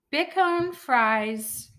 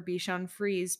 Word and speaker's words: Bichon Frise: Big Cone Fries for Sale Tulsa. – No, pronounce it how you want to pronounce it. Betsy, close your ears. – Bitch on Fries for Sale Tulsa. What is this? – I was actually looking up a rescue for Bichon Frise puppies.